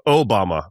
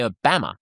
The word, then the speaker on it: Obama: Obama. We really lean into the O, Obama. And the Brits, you know, the Brits, they drove me, it shat me to tears. They would always say Obama.